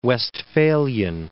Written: Westphalian

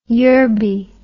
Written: Yerby

